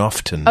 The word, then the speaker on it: often: It's like often. – Right. – It was specifically taught. Don't say the T in often.